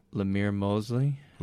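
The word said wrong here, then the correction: Lorimer Moseley: Lorimer Mosley. Let's call him L. – L.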